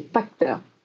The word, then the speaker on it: Pachter: Parter, Parcher. – Alors, la prononciation, c'est Pachter. dans ma vidéo, j'ai dit Parter parce que je l'avais mal entendu, mais je trouvais que c'était plus marrant de l'appeler Parter parce que c'est le Parter.